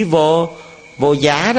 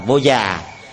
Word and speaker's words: vô già: Cái chữ này đọc chính xác, đọc đúng, nó phải là vô già nó mới đúng. Nó có hai âm, một âm giá, một âm già, mà cái âm ở đây chúng ta là âm già mới đúng.